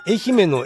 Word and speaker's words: 愛媛: 愛媛の絵と娘の名前を説明すると、姫の字が頭に浮かぶのか、めんどくさい字を使ったねとよく言われる。かっこ、三女、よしえ、三田よしこのよしに愛媛の絵。ちなみに最後に、ところで愛という字は絵と読むのとよく言われる。